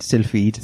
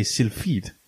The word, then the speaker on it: Sylphid: Because obviously being English, well, English slash British slash lala, we pronounce the PH as f-f. So we're inclined to say Sylphid. – Mm. – Whereas it might just be two separate like syllables. So Sylphid.